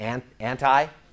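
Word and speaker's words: anti (ἀντί): The Greek word for is anti, transliterated into English characters, A-N-T-I, like anti, but it's pronounced anti.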